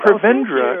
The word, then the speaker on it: Prevendra: Prevendra oh, comes and from the Spanish root word.